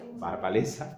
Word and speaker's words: Wałęsa: Entonces, digamos, como saben, Lech Wałęsa, que es como se pronuncia en polaco, Walesa, pero lleva una cosa a la L.